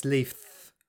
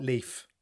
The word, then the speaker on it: Leith: Apparently, according to my Scottish mates, when I'm living with them in Leaf, funny enough, in Scotland, I always say it wrong. It's Leith, not Leaf.